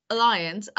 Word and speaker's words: Allianz: Allianz, I think I spoke to someone about this yesterday, and it's pronounced Allianz. – Aliens, okay. Aliens.